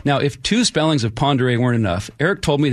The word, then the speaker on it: Ponderay: Now, right next door in Idaho, there's a town on Lake Ponderay called Ponderay, but spelled phonetically P-O-N-D-E-R-A-Y.